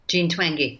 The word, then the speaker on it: Twenge: Jean Twenge.